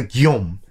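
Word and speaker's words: Guillaume: 어, 프랑스 노르망디에 있을 때 이름은 기욤이에요. 아, 정말 귀여워! 할 때가 아니라 기욤. 기욤이라는 프랑스인들 이름 많잖아요. 자, 똑같은 이름이에요. 영어로 하면 윌리엄, 똑같은 스펠링. 영어로 하면 윌리엄, 프랑스어는 기욤, 그 다음에 독일어는 빌헬름이요.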